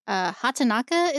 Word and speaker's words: Hatanaka: So MAD COWGIRL is a low-budget film by Gregory Hatanaka, is I believe how you'd pronounce that,